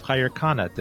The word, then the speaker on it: Khair Khana: Khair Khana.